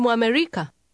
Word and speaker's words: Mwamerika: With the "mm" sound at the beginning, that means a person from America. How does she ask, "Are you American?" – Wewe ni Mwamerika?